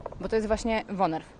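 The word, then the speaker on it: woonerf: Mówimy wonerw, wunerw, z urzędników różnie przypadają określenia. – Było sprawdzane. Słowo jest konkretne, pochodzi z języka niderlandzkiego i prawidłowo je się wymawia jako wonerw.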